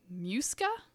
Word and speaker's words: Muisca: Anyway, so yes, the legendary land of Muisca, M-U-I-S-C-A, Muisca, aka Chibcha.